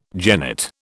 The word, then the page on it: genet: British English